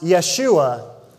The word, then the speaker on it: Yeshua: Jesus in Hebrew is pronounced Yeshua, which means salvation.